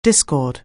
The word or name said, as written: discord